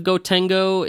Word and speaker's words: Gotengo: The go at the end of Gotengo is part of the Japanese pronunciation, but it made its way into the Western, I guess, the way we talk about it. So it's actually the Gotengo.